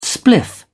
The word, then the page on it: spliff: British English